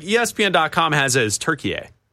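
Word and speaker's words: Türkiye: They pronounce it turkey A. – Turkey A. – Okay, like, so over we'll there, it's pronounced turkey A.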